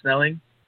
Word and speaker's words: Snelling: My YouTube channel is my name, Jackson Snelling. – Perfect. – And it's spelled my last name is Snelling, and Jackson's just Jackson.